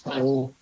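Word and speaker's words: xanh: There's also Grab, and it's pronounced Sang. You can call it Green. It's X-A-N-H.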